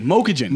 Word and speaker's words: Mokujin: Mokujin. – Mokujin. – Yeah, Mokujin.